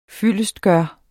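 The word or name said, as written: FYLDESTGØRES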